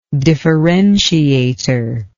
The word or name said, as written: differentiator